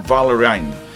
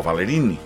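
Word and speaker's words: Ballerini: Kelsey Valerine, o Valerini, com també es pronuncia, depèn de l'ascendència que tinguis.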